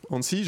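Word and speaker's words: ANSSI: Hansi, je le prononce bien, c'est ça Oui, c'est ça, exactement.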